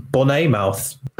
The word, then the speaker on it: Bournemouth: Very good Davis has gone to Bournemouth. – I believe it's pronounced Bonnemouth.